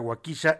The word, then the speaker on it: Waukesha: Y esta tragedia ocurrió en la ciudad de Waukesha, se pronuncia Waukesha en Wisconsin, allá en los Estados Unidos.